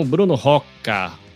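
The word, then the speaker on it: Bruno Rocca: Estou aqui com o Bruno Roca, se é que é assim que pronuncia seu nome.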